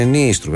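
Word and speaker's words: Nystrup: Θέλω να πω ότι ευτυχώς που είδαμε αυτό το βίντεο, γιατί ακούσαμε τον Δανό κύριο να προφέρει το όνομα του Νίστρουπ, το οποίο είναι Νίστρουπ. Ακούγονται δύο Ι.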